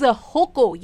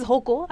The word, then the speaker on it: होको: होको. Yes the pronunciation is होको. I know बहुत difference of opinion है ambiguity है इस बात में कि आखिर इसको बोलते क्या है बट एक्चुअली में it's होको and they have been fulfilling और सैटिएटिंग द टेस्ट एंड फ्लेवर्स ऑफ इंडियंस अक्रॉस जनरेशंस.